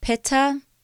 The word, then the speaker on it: Pitta: Vata, which is spelled V-A-T-A, Pitta, P-I-T-T-A, and Kapha, K-A-P-H-A. Vata, Pitta, and Kapha.